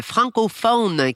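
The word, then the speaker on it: Francofaune: On s'en va en Belgique au festival Francophone qui se tient, j'ai bien prononcé là, faune comme la faune, la flore, qui se tient à Bruxelles.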